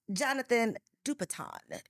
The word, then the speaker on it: Jonathan Dupiton: Jonathan Dupiton is in the studio.